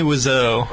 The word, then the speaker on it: Wiseau: Tommy Wiseau.